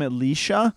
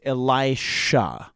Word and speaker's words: Elisha: Um, Elisha, we often called him Elisha, but his name is actually pronounced Elisha.